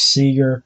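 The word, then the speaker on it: Cugir: It looks like Cougar, but it's pronounced Seeger.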